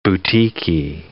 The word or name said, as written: boutiquey